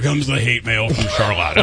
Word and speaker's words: Charlotte: You also share your birthday with cool Michiganders like Dustin Klaus of Charlotte, which is how that's pronounced.